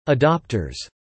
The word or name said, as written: adopters